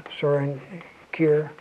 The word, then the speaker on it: Søren Kierkegaard: Now Kierkegaard, Søren Kierkegaard, I may have warned you already, don't say, be sure to pronounce this at least half decently, Søren Kierkegaard.